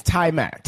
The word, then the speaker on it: Taimak: Tarmac? – That's right. – Yeah, it is. – It's Tarmac. – Tarmac. – It's Tarmac.